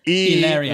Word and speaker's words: Hilaria: So that's how she pronounces Hillary. I didn't know that Hillary was Hilaria.